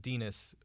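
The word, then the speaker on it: Denis: Dinas, parenthetically, as Pynchon notes, rhymes with penis.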